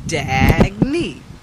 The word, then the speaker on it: Daggy: How I explain my name is Dag, like Dag and Knee. Dag Knee. That's how you say it.